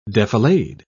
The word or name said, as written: defilade